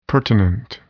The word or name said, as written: pertinent